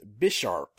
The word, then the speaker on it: Bisharp: And finally, Bisharp is actually pronounced Bisharp.